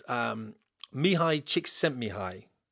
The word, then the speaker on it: Csikszentmihalyi: And how you pronounce it is Mihai Csikszentmihalyi.